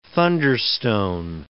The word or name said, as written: thunderstone